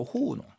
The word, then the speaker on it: 糊弄: 발음에 유의해야 되죠. 이 농이 이제 경성으로 호우농 이렇게 읽습니다.